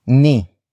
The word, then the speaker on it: ni: Up next we have ni. Ni. Ni. Ni is spelled as N I.